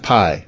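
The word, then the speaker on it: Pii: So Chef Pi, I think, is how she pronounces her name. It's P-I-I.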